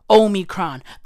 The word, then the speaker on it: Omicron: The word is Omicron. That's how you say it. That's how you pronounce it.